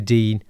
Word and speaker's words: Digne: Coutinho and Dina, you know, I think it's, or Dean, sorry, Luca Dean is how it's pronounced apparently.